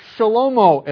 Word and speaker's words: Shlomo: And you hear it also in the name of Solomon or Shlomo, as it's pronounced in Hebrew.